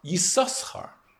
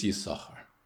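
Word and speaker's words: Yissachar: that the name is pronounced Yisoschar until Parshas Pinchas. And from Parshas Pinchas and onwards, it's Yisachar.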